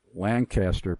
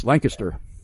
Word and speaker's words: Lancaster: I have to constantly remind myself that it is not Lancaster, Pennsylvania, as in Burt Lancaster. It's Lancaster.